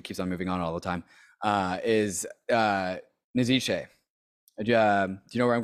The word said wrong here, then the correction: Nietzsche: Sorry, Nietzsche.